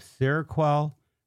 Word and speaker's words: Seroquel: Miscellaneous antipsychotic agents like Seroquel, I think that's how it's pronounced. – Yep. – So we have Seroquel, Zyprexa, and Risperdal.